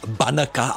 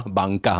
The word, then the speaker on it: banka: Za chvilinku, no nic se nemá přehánět, ono zase potom říkat banaka, hanaka, je nejenom protivné, ale vlastně i proti správné výslovnosti toho velárního banka, hanka, tak je to správně.